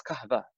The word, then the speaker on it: kahve: In Turkish language, they have a hard time pronouncing the Arabic letter wow. So they say, instead of wali, you know, they'll say veli, the V. – Yeah. – Kahwa becomes kahva, K-A-H-V-E.